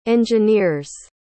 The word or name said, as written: engineer's